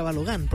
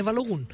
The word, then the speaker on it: Balogun: que yo hasta antes de la Copa del Mundo muy mal le decía Balogun, porque yo creía que así se pronunciaba, digamos, en norteamericano, entonces termina en U, debe ser Balogun. No, es Balogun.